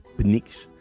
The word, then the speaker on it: Pnyx: que és difícil de pronunciar, P-N-I-N-X, Pnyx,